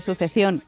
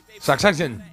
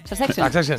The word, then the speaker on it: Succession: a la serie que siempre pronuncio mal, que es la de Sucesión. – Succession. – Succession.